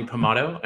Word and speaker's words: Pomatto: My name is Johnny Pomatto, and I'm a sometimes actor, a writer, and occasional podcaster myself.